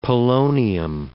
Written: polonium

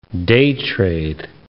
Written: day-trade

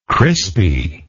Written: crispy

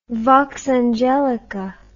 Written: vox angelica

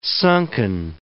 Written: sunken

